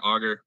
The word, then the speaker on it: Auger: And OJ is spelled, it's spelled like auger, which is usually what I get when I'm talking to, you know, new people, but it's spelled A-U-G-E-R.